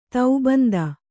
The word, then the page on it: ತೌಬಂದ: taubanda